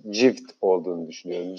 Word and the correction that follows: gift: Cift bu arada İngilizce hediye, armağan gibi düşünün yani. Gift diye okunuyor diyor Mutlu.